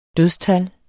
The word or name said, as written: dødstal